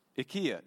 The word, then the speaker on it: IKEA: IKEA, or IKEA, IKEA is how you say it in Swedish, by the way. So if you want to sound Swedish when you go to IKEA, uh, IKEA, say IKEA.